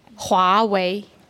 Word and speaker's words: Huawei: Huawei, Hua.